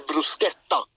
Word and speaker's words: bruschetta: Ja, just det, och där har vi den där H-grejen. Det blir bruschetta. – Okej. – Okay. Det är K.